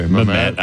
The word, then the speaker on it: Mehmet: Mehmet. – I put the emphasis on the wrong syllable.